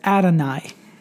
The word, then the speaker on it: Adonai: That is the word Yahweh, the word Lord, which also is pronounced every time it's read in the Hebrew Bible, that is, as Adonai.